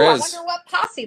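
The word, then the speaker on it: Pasi: And I just want to point out that the author, his name, do you know how to pronounce it? Is it Posse? – Posse, yep.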